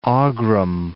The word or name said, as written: Aghrim